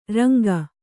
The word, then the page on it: ರಂಗ: ranga